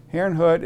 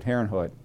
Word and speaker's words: Herrnhut: Herrnhut is spelled H-E-R-R-N-H-U-T.